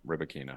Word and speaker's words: Rybakina: us to pronounce her name, Rybakina.